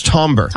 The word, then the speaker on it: timbre: It's timbre. – But it's spelled timbre.